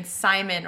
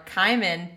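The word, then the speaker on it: CIMON: Well, there is a maybe gendered robot if you pronounce C in Simon or Kaiman, the floating space brain.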